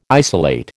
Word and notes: isolate: American English

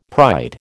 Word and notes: pride: American English

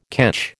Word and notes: ketch: American English

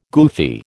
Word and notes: goofy: American English

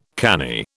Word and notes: canny: British English

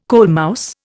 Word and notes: coalmouse: Indian

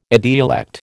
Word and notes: idiolect: American English

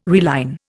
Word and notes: reline: Indian

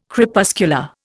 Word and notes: Crepuscular: Indian

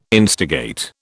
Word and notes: instigate: British English

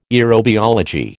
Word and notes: aerobiology: American English

